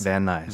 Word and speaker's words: Van Nuys: You mean Van Nuys? – Is that how you say it? – Yeah. – N-U-Y-S? – Van Nuys. – Van Nuys, okay.